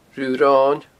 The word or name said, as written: Rubh' an Roin